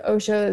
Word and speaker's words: Osho: Like, you know, is it Osho that said all of life is, or the all of life is an orgasm? Am I saying that right? Yeah.